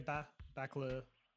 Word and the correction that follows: Bakalova: How do you pronounce the last name? You said it before. – Maria Bakalova. – Maria Bakalova. Okay, thank you.